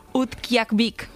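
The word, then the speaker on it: Utqiaġvik: Utqiaqvik.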